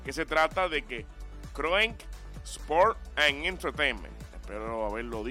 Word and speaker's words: Kroenke: Kroenke Sports and Entertainment.